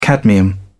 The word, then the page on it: cadmium: British English